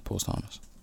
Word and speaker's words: posthumous: Posthumous. – Is that how you pronounce that? – Yeah, posthumous.